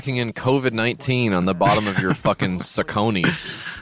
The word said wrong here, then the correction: Saucony: It's pronounced Saucony, I think. – I've always said Sacconis, and I'll never improve myself or change, I think, as we discussed earlier, Dan.